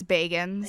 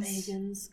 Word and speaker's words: Bagans: I think it's Bagans. – Bagans, because then he'd be a hobbit otherwise. Baggins. yeah, he's not a hobbit. – Baggins he's not cool enough.